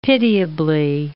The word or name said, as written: pitiably